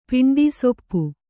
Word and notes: ಪಿಂಡಿಸೊಪ್ಪು: piṇḍi soppu

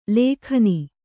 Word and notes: ಲೇಖನಿ: lēkhani

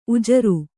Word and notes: ಉಜರು: Kannada

